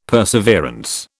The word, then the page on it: perseverance: British English